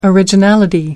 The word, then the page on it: originality: American English